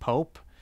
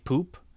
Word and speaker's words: Pope: It was nominated for Best Cinematography, cinematographer Dick Pope. And whoever read out the nominations pronounced his name Dick Poop, which, jeez.